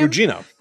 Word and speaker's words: Gugino: I thought that the mom kind of looked like Carla Gugino, or however you say her name. – Gugino. Carla Gugino.